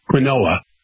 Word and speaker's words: quinoa: Now it's spelled Q-U-I-N-O-A. It looks like quinoa. Quinoa. It's pronounced quinoa.